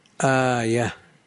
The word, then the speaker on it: آية: قوله آية الإيمان، آية بالهمزة الممدودة وياء تحتية ومفتوحة وهاء التأنيث،